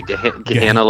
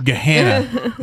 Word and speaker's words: Gahanna: It's Gahanna, right? I never say it right, I feel like. – It's, what do they say? Gahanna like banana? – Gahanna. It's Gahanna over at Nostalgia.